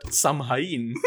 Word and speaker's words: Samhain: And Halloween's origins date back to the ancient Celtic festival of Samhain, S-A-M-H-A-I-N, pronounced Samhain. The Celts... – pronounced Samhain, but he says Samhain. – It's spelled Samhain, but it's pronounced Samhain.